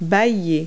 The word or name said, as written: yawn